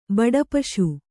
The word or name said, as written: ಬಡಪಶು